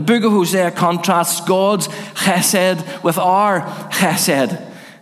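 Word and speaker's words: chesed (חסד): It's the Hebrew word chesed. Chesed. It's spelled H-E-S-E-D. Some people start it with a C.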